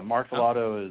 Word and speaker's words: Martellotto: Yeah, Martellotto is the American pronunciation that works.